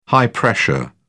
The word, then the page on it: high pressure: British English